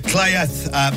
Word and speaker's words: Cleddau: Oh, yeah, tasting two beers from the Cleath Brewing Company. It's spelled Cleddau.